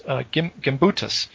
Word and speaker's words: Gimbutas: Old Europe is a term coined by archaeologist How do you pronounce her name again? Maria Galumbas? Is that it? Darn, I said... Yeah, here it is. Gimbutas. Maria Gimbutas.